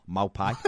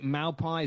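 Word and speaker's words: Maupay: I struggle with the pronunciation of, is it Maupai, is it Mopai or whatever? And I get that. And people say different things.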